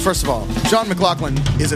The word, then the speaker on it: McLaughlin: Pronounced McLaughlin. – That's right. – Yeah, you look up the other guy. – That's right. So J-O-N McLaughlin is really handsome, good-looking dude that writes pop music, incredible piano player.